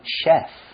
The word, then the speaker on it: chef: So she can cook and she's good at it, but it's not her job, so we can't call her a chef. – OK, 我懂了。其实像是职业上，像是餐厅的厨师或是主厨，我们就可以用 chef, C-H-E-F 来表示哦。那注意它的发音，这个 C-H 是发 sh 的音哦。那其实就像我们的阿基师，他简直就是厨神级的啦。那我们就可以称他为这个 master chef.